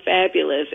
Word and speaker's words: Fabulous: It's spelled 5BULOUZ, but it's pronounced Fabulous.